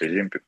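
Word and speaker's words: Ozempic: Точнее, правильно говорить, Озимпик, наверное, да.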